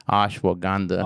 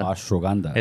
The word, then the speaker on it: ashwagandha: Ashwagandha. Ashwagandha.